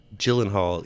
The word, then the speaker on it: Gyllenhaal: I'm going with Gyllenhaal, even though I just said Gyllenhaal. I'm going to just take that Gyllenhaal right back, replace it with the soft G.